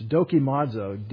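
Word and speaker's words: dokimazo (δοκιμάζω): It's dokimazo, dokimazo. We'll do it there. Dokimazo, dokimaz. That's a long O.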